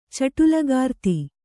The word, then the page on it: ಚಟುಲಗಾರ್ತಿ: kannada